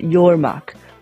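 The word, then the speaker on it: yoğurmak: The word yogurt itself comes from the Turkish word yormak, with a soft G, which means to thicken or to curdle.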